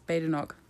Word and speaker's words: Badenoch: I've been pronouncing her name wrong. It's Badenoch. My bad, girl.